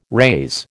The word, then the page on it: raise: American English